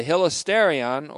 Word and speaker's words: hilasterion: Now, this may be related to the fact that the related word hilasterion, that's H-I-L-A-S-T, long E-R-I-O-N, the hilasterion, or the cover,